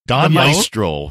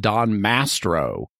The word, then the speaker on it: Maestro: I paid careful attention to how both he and Charlie pronounce it. And they both initially pronounce it Don Mastro. – Yeah. – Which is even worse. It's not even real Italian.